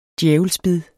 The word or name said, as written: djævelsbid